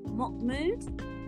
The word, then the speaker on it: Mocmoud: So you said it's Prophet Mahmood? – Mahmood, yes, perfect. Prophet Mahmood.